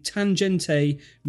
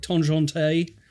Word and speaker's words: Tangente: And this is a classic timepiece, the Tangente Midnight Blue. Now, am I saying that correctly? Is it Tangente? Tangent is probably how I would go with it.